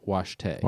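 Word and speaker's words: wašté: It's spelled waste for some reason, but it is pronounced Washte.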